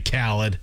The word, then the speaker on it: Khaled: Yeah, it's DJ Khaled.